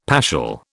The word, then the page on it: paschal: British English